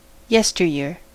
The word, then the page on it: yesteryear: American English